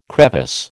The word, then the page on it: crevice: American English